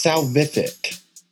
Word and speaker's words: salvific: How do you pronounce salvific? Or did I butcher it? – No, you were close. So it's salvific.